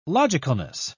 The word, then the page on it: logicalness: British English